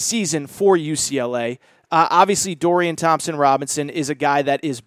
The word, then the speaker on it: Thompson-Robinson: Again, Dorian Thompson-Robinson. Okay, I did say it right. I was going to say Robbins.